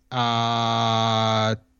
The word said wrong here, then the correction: Taika Waititi: How do you say that guy's name? – Taika Waititi.